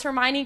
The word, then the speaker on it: Hermione: And actually, I had an argument with my dad too, because he read the books and he knew it was Hermione because there's some old actress like in old movies in the 50s and 60s whose name is Hermione.